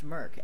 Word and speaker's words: MERC: I think it's pronounced Merc, M-E-R-C, which is kind of an acronym for Mos Eisley Radio Community.